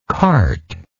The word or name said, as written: carte